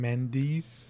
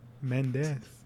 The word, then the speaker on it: Mendes: So this is, of course, directed by Sam Mendes. As Americans say. Mendes, as we say.